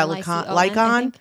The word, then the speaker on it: Licon: Lyko? How do you say his last name? Yeah, Lycon. L-I-C-O-N, yeah.